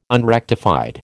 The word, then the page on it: unrectified: American English